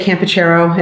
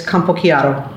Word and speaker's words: Campochiaro: So in American, I say Campochiaro, and in Italy, it's Campochiaro.